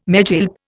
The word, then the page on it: megilp: Indian